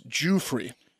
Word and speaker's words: Giuffre: I believe Mark was going to say Virginia Giuffre. – It's Giuffre.